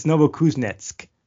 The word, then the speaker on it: Novokuznetsk: So he came back and he's like, it's Novokuznetsk. I'm like, oh yeah, I wouldn't have gotten that from just looking at it.